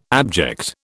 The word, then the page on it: abject: British English